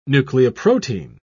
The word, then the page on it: nucleoprotein: British English